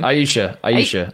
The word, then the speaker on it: Ayesha: How do you say her name? – Aisha.